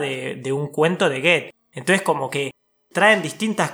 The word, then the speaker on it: Goethe: Goethe,